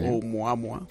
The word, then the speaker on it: Oumuamua: Estoy sorprendido por tu pronunciación del hawaiano. – Sí. – Oumuamua. Muy bien, perfecto.